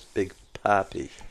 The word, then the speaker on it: Papi: Boys in Colorado, you pronounce this as Big Poppy. – Poppy, not Poppy. Poppy, P-A-P-I. – Not like the poppy plant that you get cocaine from.